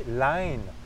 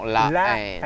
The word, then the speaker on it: La Haine: J'ai compris. La Haine. – Non, La Haine.